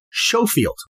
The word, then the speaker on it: Schofield: Let's first note that this woman's name is spelled S-C-H-O-F-I-E-L-D. Sometimes the S-C-H could be pronounced as S-H, as in Schofield. Other times, S-C-H could be pronounced as S-K, as in Schofield. I have found myself sometimes pronouncing it the first way, Schofield, and other times pronouncing it the second way, Schofield.